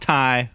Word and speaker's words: Tay: It's spelled Tay, but it's pronounced Ty.